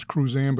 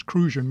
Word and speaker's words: Cruzan: If you look at something like Bacardi or Crujean, which most people will unfortunately pronounce Cruzan, but it's Crujean, made in St. Croix.